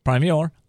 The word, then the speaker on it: Primior: Okay, of a business called Primior, right? – Primior. – Okay, how do we spell that? – P-R-I-M-I-O-R.